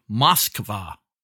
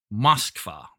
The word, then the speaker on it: Moskva: This is the Russian ship Moskva. Moskva. That's how you pronounce that. Moskva. It's actually Russian for Moscow.